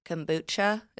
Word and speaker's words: kombucha: Kombucha.